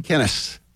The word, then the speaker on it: Kenneth: Kenneth. – Kenneth. – Yeah. – Yeah. – Rhymes with tennis.